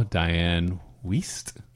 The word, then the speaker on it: Wiest: So it starred the King of Connections, Kevin Bacon, as Ren, Laurie Singer, John Lithgow, Diane Wiest.